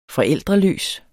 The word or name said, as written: forældreløs